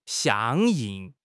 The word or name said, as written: 相应